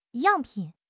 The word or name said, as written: 样品